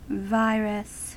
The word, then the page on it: virus: American English